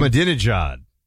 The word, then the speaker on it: Ahmadinejad: Mahmoud Ahmadinejad or whatever he pronounces his name. – Ahmadinejad.